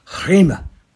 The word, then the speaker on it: rhema: This word for word is the word rhema. It's pronounced rhema.